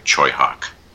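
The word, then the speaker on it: Tsui Hark: How about Sweehark's Vampire Hunters? Did I say that right? – Fucking loved it! – Tushihark, isn't it? – Pronounced in Cantonese, it would be closer to Choi Hawk.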